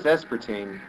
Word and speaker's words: aspartame: Because I keep pronouncing it aspartame. – I thought it was aspartame. – Yeah, it's aspartame, actually.